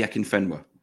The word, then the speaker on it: Akinfenwa: Is the I might pronounce his name here wrong, but Afakinma. – Adi Akinfenwa.